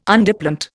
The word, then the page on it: Underplant: Indian